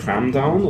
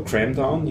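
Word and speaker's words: kramdown: Und der heißt Cramdown oder Cramdown, je nachdem, ob man es Englisch aussprechen will oder nicht der eine äh, reine Ruby-Implementierung ist.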